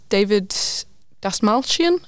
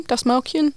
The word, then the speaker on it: Dastmalchian: And stars David Dastmalchian, I think you say his name, Dastmalchian.